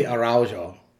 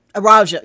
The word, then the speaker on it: Araújo: It's probably Araújo. – Araújo, yeah. – Like Araújo. – That, yeah, that sounds closer to the pronunciation that I listen to. So, yeah. Beth de Araújo. – Yeah. – I know, and I keep wanting to make it more Spanish, and like, no, that J has a sound.